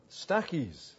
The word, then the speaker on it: Stachys: Verse 9, my beloved Stachys, that's how you pronounce it.